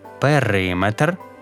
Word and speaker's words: периметр: Міри довжини – міліметр, кілометр, сантиметр, але периметр, барометр, термометр, бо це не міри довжини.